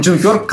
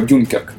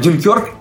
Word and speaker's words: Дюнкерк: В общем и целом, Дюнкерк классный. Он классный. И, кстати, я до просмотра называл его Дюнкерк. А оказывается, ударение все-таки на Е, да.